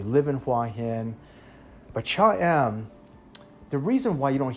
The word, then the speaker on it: Cha-am: This beach city is called Cha Em. It's spelled C-H-A-A-M, Cha Em.